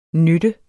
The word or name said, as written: nytte